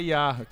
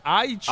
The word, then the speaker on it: Haiti: Não, não é IA, calma, é Haiti. – Haiti, né? E... me pronunciei errado aqui, Haiti.